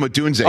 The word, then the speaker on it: Odunze: Rome Odunze.